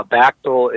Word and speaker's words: Bachtell: Now, Bachtell is the, the president, the general secretary of Communist Party USA, and his last name is spelled B A C H T E L L, John Bachtell, and go to, well, among others, his, his 2016 uh, party platform speech.